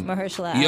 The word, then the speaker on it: Mahershala: Oh, Mahershala? – I can't say his name. – Mahershala.